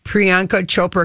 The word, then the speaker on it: Priyanka Chopra: One of our listeners gave us the pronunciation, Priyanka Chopra, because I said if we have any chance of her saying hi, we have got to get the name.